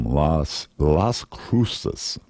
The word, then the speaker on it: Las Cruces: Let's try that again. La Cruces, a brand new album. Boy, I'm not saying that correctly, am I? Silent Night. Boy, it is a really good album. Las Cruces. Let's go with that.